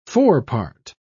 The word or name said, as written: forepart